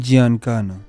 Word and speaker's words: Giancana: Sam Giancana.